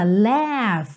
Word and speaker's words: laugh: L-A-U-G-H. E a pronúncia dela é laugh laugh, como se fosse L-E-F mesmo, tá? Laugh. Não precisa nem th, th, th. É um som de F mesmo, laugh.